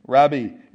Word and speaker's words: Rabbi: And they said, Rabbi or Rabbi, however you want to pronounce it. Rabbi is the way it's pronounced in the Greek, which means teacher.